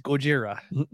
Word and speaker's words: Gojira: It's Gojira. That's probably not appropriate. – No, because there's a band named Gojira, so it's okay. – And that's technically how it's pronounced, right? – Yes.